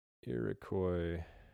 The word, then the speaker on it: Iroquois: It looks like it is, because I think Iroquois. You're absolutely right, because it ends with an S that we don't pronounce.